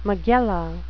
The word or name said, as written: megillah